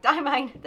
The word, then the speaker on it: Diamine: Diamine. 9 years I've been saying it wrong. Diamine.